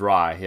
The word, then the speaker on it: Satyajit Ray: but Satyajit Ray is how it looks on Wikipedia, at least. – That's pretty good, because because it's spelled Ray, but I heard too that it was Rai.